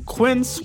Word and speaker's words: Quince: Today Explains support today comes from Quince, which rhymes with since but is spelt with a Q-U.